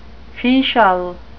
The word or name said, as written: fetial